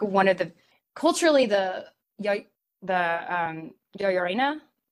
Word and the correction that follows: La Llorona: Did I say that correctly? I did not say that correctly, did I? The weeping woman, the crying woman, the vengeful ghost from Mexico. – Oh, La Llorona? – Yeah, Llorona.